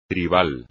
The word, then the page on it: tribal: espagnol